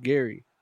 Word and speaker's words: Gerry: So this fool's name is Gary, but with G-E-R-R-Y? – Yeah, he goes, it's pronounced Gary.